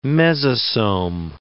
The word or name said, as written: mesosome